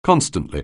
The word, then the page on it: constantly: anglais